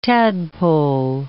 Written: tadpole

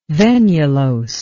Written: venulose

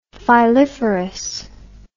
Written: filiferous

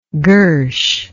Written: girsh

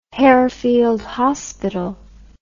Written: Harefield Hospital

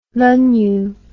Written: lunyu

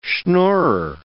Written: schnorrer

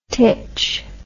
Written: tich

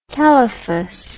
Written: telephus

